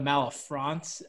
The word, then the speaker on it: Malafronte: You get a lot of Malafronte. Okay. The spelling more so. Not so much the pronunciation, more so the spelling.